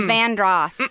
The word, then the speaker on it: Vanderhorst: Vandross Street is spelled V-A-N-D-E-R. H-O-R-S-T, Vanderhorst. But it's Vandross. – And Hugie? – A Hugie Street. – Hugie. – Which is H-U-G-E-R.